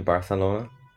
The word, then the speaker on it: Barcelona: Are you in Barcelona? – Is that how that is how they say it, huh? With like a weird lispy Barcelona. – TH. Barcelona.